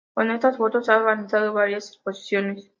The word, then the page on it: exposiciones: Spanish